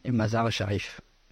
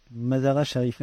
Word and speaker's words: Mazar Sharif: Euh, oui, Mazar Sharif. Mazar Sharif.